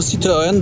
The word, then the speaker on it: Citroen: Z tego, co pamiętam, to był Citroen 2CV.